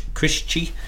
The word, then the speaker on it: Crisci: Crisci. Is I think correct actually in terms of Italian pronunciation, from Toscana in Italy.